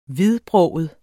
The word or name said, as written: hvidbroget